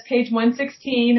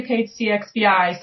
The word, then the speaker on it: Page CXVI: You know, Dan, our drummer, says Page 116, and I say Page CXVI.